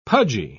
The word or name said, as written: pudgy